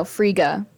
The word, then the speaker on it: Frigga: Frigga. – Frida. Yeah. – No, Frigga with a G, with two G's Friga.